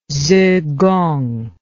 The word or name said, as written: zigong